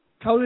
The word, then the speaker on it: cawl: Cowl is a Welsh word, Rob. Oh, it's spelled C-A-W-L, and it's a mixture of vegetables and stuff that we make into a soup.